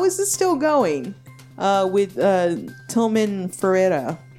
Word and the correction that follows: Fertitta: Fertitta? – Fuck him.